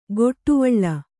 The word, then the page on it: ಗೊಟ್ಟುವಳ್ಳ: goṭṭuvaḷḷa